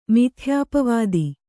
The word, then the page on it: ಮಿಥ್ಯಾಪವಾದ: mithyāpavāda